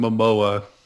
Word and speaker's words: Momoa: Jason Momoa.